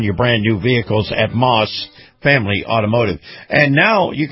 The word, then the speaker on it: Maus: And this portion of the program brought to you by our very good friends at the Moss Family Automotive Group. Moss is spelled M-A-U-S.